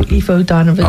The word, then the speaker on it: Aoife O'Donovan: Say the next name for me because I can never pronounce it. – Oh, Aoife O'Donovan. – Ah, there it is.